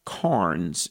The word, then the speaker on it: Cairns: But there's a man, Earl Carnes. It's spelled C-A-I-R-N-S. Earl Carnes, Christianity Through the Centuries.